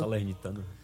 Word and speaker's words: Salernitana: Onde ele na Salernitana, se eu tiver algum italiano aí, puder me corrigir, estiver falando errado. – Salernitana.